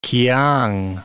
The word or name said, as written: kiang